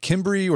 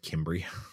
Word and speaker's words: Cimbri: These were the, is it the Cimbri or the Cimbri? Yeah, I mean, you know, everybody has their preferred pronunciation. I've always gone with Cimbri.